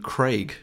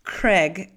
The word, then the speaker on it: Craig: Craig is what I should say. Craig, like an egg. – Craig to rhyme with Greg. No, Craig to rhyme with bagel.